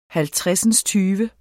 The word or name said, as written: halvtredsindstyve